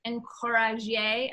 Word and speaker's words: encourager: Encourage is from the Old English, encourager. I'm sure I'm pronouncing that perfectly. – Exactly right, yes.